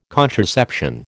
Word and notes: contraception: American English

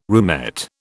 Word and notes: roomette: British English

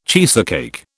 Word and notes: cheesecake: British English

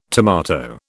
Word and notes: tomato: British English

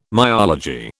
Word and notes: myology: British English

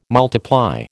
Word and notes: multiply: American English

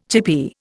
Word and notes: chippy: Indian

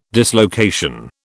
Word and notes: dislocation: British English

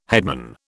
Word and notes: headman: British English

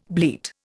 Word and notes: bleat: Indian

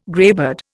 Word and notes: greybeard: Indian

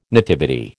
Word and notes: nativity: American English

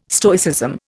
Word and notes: Stoicism: Indian

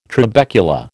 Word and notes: trabecula: American English

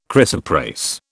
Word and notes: chrysoprase: British English

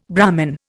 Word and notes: Brahmin: Indian

